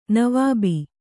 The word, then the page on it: ನವಾಬ: Kannada